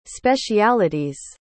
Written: speciality's

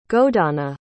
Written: godana

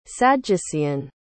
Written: sadducean

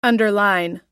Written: underline

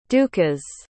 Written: dukas

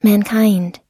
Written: mankind

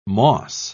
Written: moss

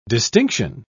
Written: distinction

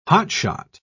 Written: hotshot

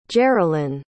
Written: jerilynn

